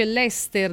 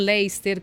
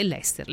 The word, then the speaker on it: Leicester: dicono si dice Leicester non Leicester come dicono erroneamente tutti in Italia, mi correggono, Leicester è Leicester, Leicester, va bene, a tra poco.